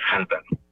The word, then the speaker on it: hanta: Entonces, el nombre correcto es Hanta, haciendo sonar la H, digamos, no Anta, pues, como muchos le dicen. – Bueno, a lo mejor Anta o Hanta, lo que pasa es que como todo nos viene de Estados Unidos, pues ellos pronuncian la H.